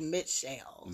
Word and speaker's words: Mitchelle: And everyone pronounces it Michelle, but it's actually Mitchelle. – So, Mitchelle? – Mitchelle, so it's M-I-T-C-H-E-L-L-E. So, I apologize. – M-I-T-C-H, isn't that how you spell Michelle? – No, Michelle, no T in Michelle.